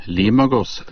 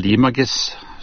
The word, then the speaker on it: Limoges: I hvilket europeisk land ligger byen Limoges, eller Limoges, er mer riktig uttalt.